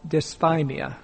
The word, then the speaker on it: dysthymia: A lesser form of depression is called dysthymia, and that's spelled D-Y-S-T-H-Y-M-I-A.